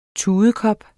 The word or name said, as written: TUDKOP